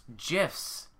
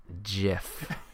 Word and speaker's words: GIF: of just memes or just gifs. Right. It. In case you're wondering, it is pronounced GIF. Peanut butter, GIF.